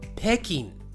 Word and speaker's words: 北京: 北京。はい、もう一度いきます。北京。はい、ベイジンですね。はい、このベイジンも日本人は違う読み方をしています。日本人の呼び方は北京。